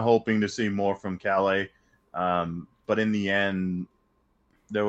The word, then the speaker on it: Calais: The other notable cuts, Raymond Calais, I think is how they kept pronouncing it, not Calais, and A.J.